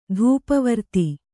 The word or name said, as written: ಧೂಪವರ್ತಿ